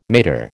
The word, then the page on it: mater: American English